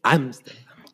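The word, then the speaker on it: Ámsterdam: De hecho, tiene un acento en la A. – Sí, Amsterdam Amsterdam Y este y pues es la nueva película de, David O.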